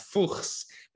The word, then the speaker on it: Fuchs: So Jun is right, and Fuchs is a German name. I'm from Switzerland, so it's pronounced Fuchs, but it translates directly into Fox in English. So I gave you the possibility to just call me Fox.